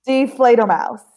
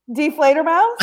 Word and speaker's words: Die Fledermaus: Die Fledermaus. – Isn't deflator like die, D-I-E, and then flater, like F-L-A-D-E-R, and then mouse, like mouse, the little mouse. Die Fledermaus.